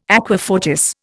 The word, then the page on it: aqua fortis: Indian